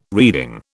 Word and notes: reeding: British English

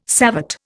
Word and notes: savate: Indian

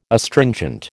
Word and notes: Astringent: American English

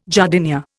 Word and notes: jardinière: Indian

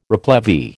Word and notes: replevy: American English

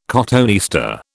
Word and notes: cotoneaster: British English

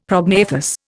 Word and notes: prognathous: Indian

